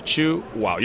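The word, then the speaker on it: Chuao: So that would be the way you pronounce our name, chew wow. You chew and you say wow.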